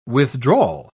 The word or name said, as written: withdrawal